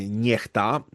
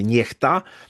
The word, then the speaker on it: NEXTA: Ewidentnie tutaj mamy do czynienia z typowymi wrzutkami medialnymi, chociażby kwestia, no można powiedzieć, takiego czarnego PR-u dla kanału Niechta, czy Nexta, jak kto woli, ale tak jak twórcy sobie życzą, czyta się Niechta.